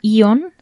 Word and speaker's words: AEON: Y luego hay otro que se llama Ion, se escribe A-E-O-N.